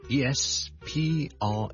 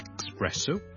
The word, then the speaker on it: espresso: 第二個字呢都是 ES 開頭的，就是 ESPRESSO espresso espresso espresso，即是意式的特濃咖啡。我們這兩個字的 ES 呢，我們都不要將它讀成 X，不要 expresso，不要 especially， but espresso， e 或者 e 都得，直接加个 S，中间唔好加咗个 K 音，